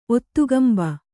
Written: ಒತ್ತುಗಂಬ

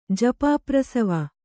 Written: ಜಪಾಪ್ರಸವ

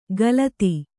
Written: ಖಲತಿ

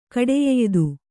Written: ಕಡೆಯೆಯ್ದು

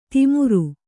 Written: ತಿಮುರು

